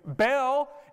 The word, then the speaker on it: Baal: I am using the pronunciation Baal. Now, if you study scholars or you study people that get a little fancy with their pronunciation, it may be Baal or something like that. I've heard a lot of different pronunciations. Baal is how I've always heard it pronounced in East Texas, and that's what I'm going with today because that's the language you speak as well.